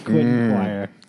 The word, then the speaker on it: Quentin Quire: I'm gonna say the name wrong, but bear with me. Quentin Q guy, you like? – Yes. Quentin Quire.